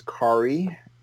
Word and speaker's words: Kari: Well, as you said, my name is Kari, rhymes with Atari, Kari Kyle Andrews, and I write and draw comic books, and in my other life, I write and direct TV and film.